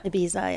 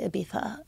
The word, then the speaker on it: Ibiza: Like, I hate when people say Ibiza. I think that's so annoying. But some Europeans say it like that, but I'm not into that. So